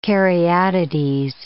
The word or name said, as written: caryatides